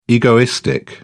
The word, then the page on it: egoistic: British English